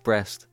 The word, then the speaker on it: Brest: Brest? – Yes.